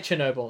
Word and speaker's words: Chernobyl: No, they say Chernobyl on the show. That's how they pronounce it.